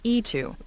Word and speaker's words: Eto'o: Eto'o. – Yeah, there you go. – E and the number two. – Apparently, yeah. That's apparently how it's pronounced.